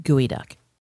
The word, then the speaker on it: geoduck: It's actually spelled like geoduck, but it's pronounced gooey duck.